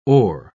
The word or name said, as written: oar